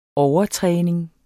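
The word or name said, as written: overtræning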